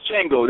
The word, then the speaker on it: Django: It's Django, that's correct.